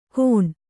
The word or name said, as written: ಘೋಣ